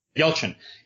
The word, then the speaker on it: Yelchin: Is that how you say his name? – Yelchin.